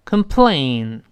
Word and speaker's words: complain: 这个投诉再强调一遍，c o m 发轻的 can can，后面是 plain 发双元音 a e a，再滑到 in in in 是这么一个声音啊。我们再读一遍，complain。 complain，我们说过吗？